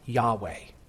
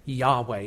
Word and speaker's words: Yahweh: In Hebrew, it's pronounced Yahweh. Yahweh.